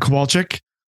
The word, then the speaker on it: Kowalczyk: It was Ed Kowalczyk.